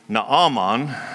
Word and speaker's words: Naaman: I'm going to say his name that way. That's how I have always heard it. I don't really think it should be pronounced that way. I looked at it in the Hebrew, and it should be Naaman. It has that extra A in there, I think because his mother probably couldn't spell real good.